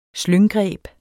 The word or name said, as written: slynggreb